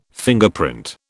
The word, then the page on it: Fingerprint: British English